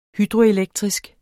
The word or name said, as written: hydroelektrisk